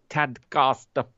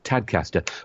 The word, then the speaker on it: Tadcaster: Get in touch with her via the forum and have a rocking Tadcaster Friday. Oh, look, I pronounced it wrong then. Oh, I'm supposed to be from Yorkshire. Tadcaster.